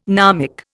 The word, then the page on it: gnomic: Indian